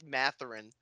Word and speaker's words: Mathurin: You absolutely butchered that name. It's Benedict Mathurin.